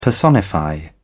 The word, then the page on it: personify: British English